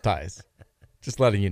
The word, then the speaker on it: Theis: By the way, apparently Daniel Theis, he pronounced the last name Theis. I didn't even know that.